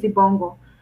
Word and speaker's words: izibongo: Um, as we go back, it's uh, so I think uh, Danielle, you did say it right. It's izibongo.